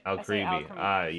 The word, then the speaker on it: Alcremie: Alcremie, how do you say it? – Alcremie, yes.